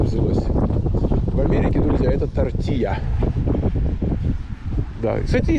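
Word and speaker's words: tortilla: Но на мексиканский вариант прононса это было бы тортия. Тортия. Два L произносится как.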